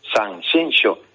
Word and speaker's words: Sanxenxo: No, Eduardo, que no, que es Sanxenxo.